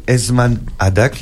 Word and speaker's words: Esman Adak: Jazia, c'est tout frais, ça s'appelle Esman Adak, est-ce que je le dis bien ? – Très bien, ah.